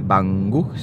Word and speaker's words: Banggood: Banggood, sí.